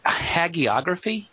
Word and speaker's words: hagiography: Hagiography? – Yeah, I think that's how you say that. Yeah. Isn't that right, how you say it? – Yeah.